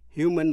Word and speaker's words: human: Human rights abuses là vi phạm quyền con người. Humane, H-U-M-A-N-E, có lòng thương người hay là súc vật. Nhân đạo, nhân đức. Phát âm, human, nhấn vần đầu.